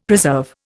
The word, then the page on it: preserve: Indian